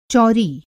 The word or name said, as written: चौंरी